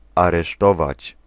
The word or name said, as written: arrêter